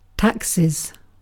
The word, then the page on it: taxes: British English